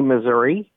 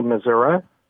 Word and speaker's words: Missouri: Well, some people say Missouri, and some people say Missouri. And it's actually politicians wrestle with how to pronounce it. And I've looked into it, and I don't think that there's a correct way of doing it.